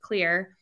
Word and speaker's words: Xlear: It's spelled X-L-E-A-R, but it's pronounced Clear.